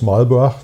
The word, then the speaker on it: Marlborough: äh, neuseeländischen Weins, kommen aus Marlborough, so wie mhm. die Neuseeländer das aussprechen.